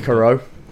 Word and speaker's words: Cicero: As I think I'm pronouncing this right, Cicero, C-I-C-E-R-O. – I can't speak Latin. – Kickero.